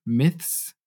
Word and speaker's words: myth: And they talked about a section called The Toxic Myths Many of Us Believe About Money. Mm-hmm. So this is a hard word to pronounce, myth, right? Especially in the plural, myths, right? The TH and the S.